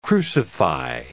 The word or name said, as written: crucify